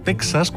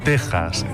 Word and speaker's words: Texas: Aunque cierto es que es más habitual escuchar Texas cuando debería ser Texas.